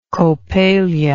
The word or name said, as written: coppelia